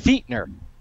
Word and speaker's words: Fichtner: His spelling is Fichtner. It's pronounced Fichtner.